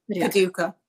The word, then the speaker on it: Paducah: So I was saying it wrong in my head the entire time. – Okay. I wasn't sure if it was Paducah or Paducah. – You now know. – Okay. – Paducah. All right. I was the emphasis on the wrong syllable for me.